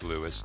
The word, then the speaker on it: Louis: It's pronounced Louis, though, typically.